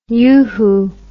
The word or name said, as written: uhu